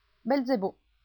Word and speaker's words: Belzebù: C'è stato un errore di pronuncia che forse riguarda più me che Cold. Ma, Linda, Belzebù, Tobias, ridendo, ha avuto difficoltà a canalizzare e pronunciare Be Zebù. Durante lo shout ha pronunciato Belizebub. Così, quando i trascrittori scriveranno, non vogliamo confonderli. Belzebù.